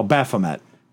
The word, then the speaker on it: Baphomet: B-A-P. – Where? – It's down here. What is that name? – Oh, Baphomet. – Baphomet came up. Like all of it came up. So that's the one that Joyce sees. Satanist churches here have that statue, Baphomet.